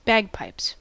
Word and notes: bagpipes: American English

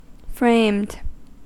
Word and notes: framed: American English

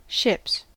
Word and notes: ships: American English

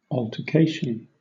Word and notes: altercation: British English

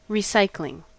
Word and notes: recycling: American English

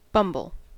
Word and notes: bumble: American English

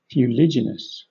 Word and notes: fuliginous: British English

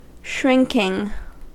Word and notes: shrinking: American English

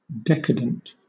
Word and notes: decadent: British English

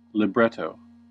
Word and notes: libretto: American English

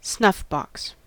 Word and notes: snuffbox: American English

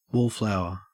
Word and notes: wallflower: Australian English

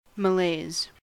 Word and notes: malaise: American English

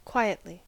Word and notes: quietly: American English

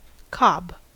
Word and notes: cob: American English

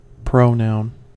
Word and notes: pronoun: American English

